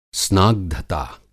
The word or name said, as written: स्नाग्घता